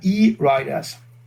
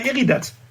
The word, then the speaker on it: E-Riderz: Und ausgesprochen, also geschrieben wird das ja E-R-I-D-E-R-Z. Und ausgesprochen halt E-Riders. – So viel zum Werbeblock. – Nein, weil viele sprechen das immer, die sagen immer Eridas. Was sind Eridas? Ich sage, ja, das sind E-Riders.